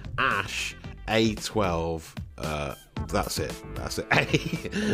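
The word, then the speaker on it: Æ: And the AE is pronounced Ash, then the A-12.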